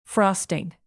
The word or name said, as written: frosting